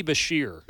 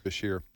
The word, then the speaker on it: Beshear: Andy Beshear. – Beshear, yeah.